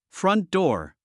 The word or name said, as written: front door